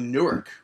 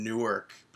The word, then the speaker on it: Newark: And Seth Rollins was discussing Jon Stewart, basically telling the fans that Jon Stewart was on the way to Newark, or Newark, as the people in Newark pronounce it. How do you pronounce it? N-E-W-A-R-K. – Newark. Newark. I go Newark. – I say Newark. Yeah, Newark. – Yeah, Newark, New Jersey. But except everybody in New Jersey apparently says Newark.